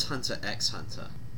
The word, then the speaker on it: Hunter x Hunter: Is the X silent? – Yes, here's the first thing you'll learn, is that the X is silent. He's a hunter of hunters, you see.